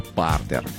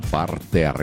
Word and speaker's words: партер: Когда мы говорим про спорт, это партер.